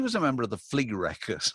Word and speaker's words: Flerekkers: ever, because it's spelled F L E R E K K E R S. How weird is that? the Flea Wreckers.